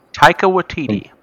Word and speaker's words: Taika Waititi: Taika Waititi. – Yeah, Taika Waititi hopes that he gets to do a Star Wars movie after Thor 4.